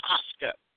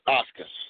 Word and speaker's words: Asuka: So hopefully she'll heal up and she'll be the one to dethrone Oksana. Am I saying her name right? – Asuka. – Asuka, thank you.